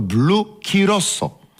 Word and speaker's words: Curaçao: Natomiast my na kursie barmańskim wymawialiśmy to Blue Curaçao.